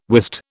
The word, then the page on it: wist: British English